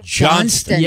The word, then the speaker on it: Johnston: Tomorrow, Buccee's will host a hiring event for their new location right here in Johnston. They plan to... – Johnston. – Yeah, because it's spelled Johnstown. – Yeah. And so, but it's Johnston.